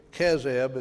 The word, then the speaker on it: Chezib: And he was at Chezib, Ke- Kezeb is the way it's pronounced, uh, when she bore him.